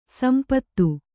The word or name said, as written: ಸಂದು